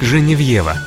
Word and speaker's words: Женевьева: Святая Геновефа более известна нам как Женевьева. Так произносят ее имя на родине во Франции.